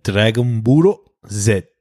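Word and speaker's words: Dragon Buro Z (ドラゴンボールZ): The most I got was Dragon Buro Z, which is how you say Dragon Ball Z.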